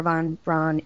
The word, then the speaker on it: Von Braun: Von Braun is.